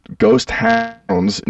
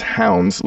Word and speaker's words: Hounds: The other thing would be, and a little self plug here, y'all should check out the Ghost Town's new single, Between Me and the Devil. That's, I say it like that because people always think I'm saying Ghost Town, but it's Hounds, like the dog.